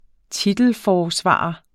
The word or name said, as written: TITELFORSVARER